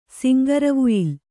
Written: ಸಿಂಗರವುಯಿಲ್

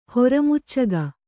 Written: ಹೊರಮುಚ್ಚಗ

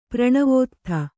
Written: ಪ್ರಣವೋತ್ಥ

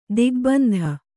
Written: ದಿಗ್ಬಂಧ

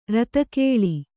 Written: ಋತುಕಾಲ

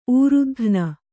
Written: ಊರುದಘ್ನ

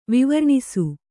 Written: ವಿವರ್ಣಿಸು

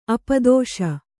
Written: ಅಪದಶೆ

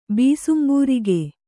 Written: ಬೀಸುಂಬೂರಿಗೆ

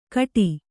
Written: ಗೇಟಿ

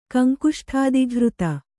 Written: ಕಂಕುಷ್ಠಾದಿಘೃತ

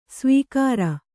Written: ಸಾವುಕಾರಿ

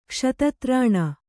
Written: ಕ್ಷತತ್ರಾಣ

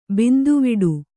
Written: ಬಂದಿವಿಡಿ

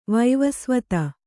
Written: ವಿವಸ್ವತ್ತು

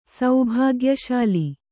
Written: ಸೌಭಾಗ್ಯಶಾಲಿ

